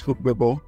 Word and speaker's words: Ogbebor: Okay, that's Ogbebor.